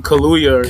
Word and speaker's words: Kaluuya: Daniel Kaluuya. – Kaluuya.